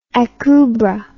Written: Akubra

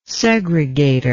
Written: segregator

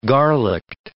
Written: garlicked

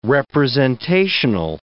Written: representational

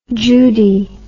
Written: judie

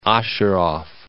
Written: Osheroff

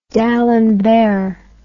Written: d'Alembert

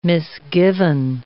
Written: misgiven

